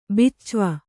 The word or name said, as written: ಬಿಚ್ವಾ